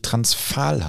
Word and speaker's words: Transvaal: Wir müssen uns nur noch einig werden, wenn es niederländisch ursprünglich ist, müsste es ja eigentlich Transvaal heißen. Ne? – Oh, da erwischt du mich auf dem falschen Fuß. Holländisch kann ich nicht. – Ja, V wird wie F ausgesprochen.